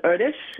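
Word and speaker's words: Erdős: Well, I believe it's pronounced Erdos.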